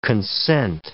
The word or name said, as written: concent